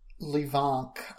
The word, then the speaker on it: Levanc: Levank. I will spell it for you. L-E-V-A-N-C.